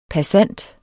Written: passant

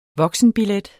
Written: voksenbillet

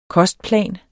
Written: kostplan

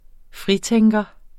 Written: fritænker

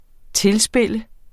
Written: tilspille

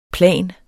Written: plan

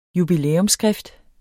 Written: jubilæumsskrift

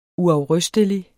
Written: uafrystelig

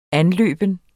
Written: anløbe

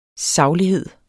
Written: saglighed

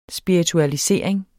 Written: spiritualisering

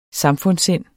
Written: samfundssind

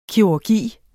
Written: kirurgi

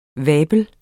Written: vabels

